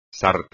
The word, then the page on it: sarta: espagnol